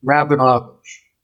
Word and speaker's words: Rabinovitch: In my family, we pronounce my surname Rabinovich. – Rabinovich? Okay. – Yeah. A little more Russian.